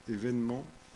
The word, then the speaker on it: événement: Mais ça se prononce événement, mais c'est quand même un accent aigu.